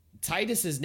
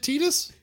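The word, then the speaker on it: Tidus: Titus's name is actually pronounced Titus. – Is it Titus? – It is Titus.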